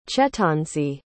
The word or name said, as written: chetanzi